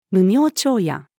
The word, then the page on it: 無明長夜: female